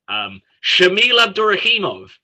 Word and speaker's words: Shamil Abdurakhimov: Shamil Abdurakhimov.